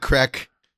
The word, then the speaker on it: craic: Yeah, had some good crack. They're all hooked on crack over there, but it's spelled C-R-A-I-C. No, they smoke crack. Yeah. C-R-A-I-C. That's their word for fun.